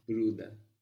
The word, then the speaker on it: Bruder: You have ER on the end of brother, but you don't really pronounce it like an ER. It's a little bit like an A. Bruder.